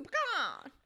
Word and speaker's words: pecan: That's just, like, for the last time, pecan, it's P-E-C-A-N, not P-E-A-C-A-N. Why are you saying pecan? There's no extra E. – It's a pecan. Pecan! – Anyways, sorry. That just always bugs me because this is around the time of year where you are going to hear people pronounce it wrong, especially through social media.